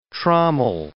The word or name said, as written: trommel